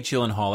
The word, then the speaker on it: Gyllenhaal: No, I think it's Jake Gyllenhaal. That's the way I've heard it pronounced, so I suspect that's the way he's been pronouncing it.